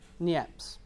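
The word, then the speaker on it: Niépce: It's taken by a chap called Joseph, let's get his pronunciation right, Niepce, French chap.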